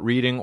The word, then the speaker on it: Reading: So I was in Delaware, and then I went to Pennsylvania at a place called Reading, which is spelled Reading. So therefore, call it Reading or change it to R-E-D-D-I-N-G.